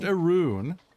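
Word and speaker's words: Arawn: Is it pronounced Arun? Interesting. – Arun, okay. – The chat yeah. – is saying it's pronounced Arun.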